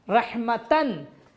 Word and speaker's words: रहमा: उसको हटाने के साथ साथ एक और काम ये करेंगे, इस ते के नुक्ते को भी हम हटा देंगे, ये बदल जाएगा हे में, रहमतन रहमतिन रहमतुन हो जाएगा रहमा, इसको हम हे में बदल देंगे रहमा, और ये साइलेंट हे होगा, साकिन हे होगा।